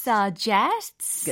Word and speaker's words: suggests: S 있는 거랑 없는 거랑 네. 잘 어마 안 나요. 아, 그렇죠. – suggest, suggests. – 오. – It's very a very subtle difference.